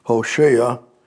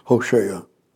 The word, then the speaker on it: Hosea: And in Hosea, picking up, if I may, and Hosea is our English language pronunciation, Hosea, long O, long A, Hosea.